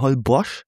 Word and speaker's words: Holbox: Unter anderem waren wir auf Holbox. Ja, es schreibt sich Holbox mit einem X, aber die Mexikaner sagen Holbox. Also das X wird anders ausgesprochen.